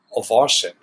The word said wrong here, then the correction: Owczarek: It's Owczarek. Yeah, he does these wonderful, and it's Marcin, Marcin.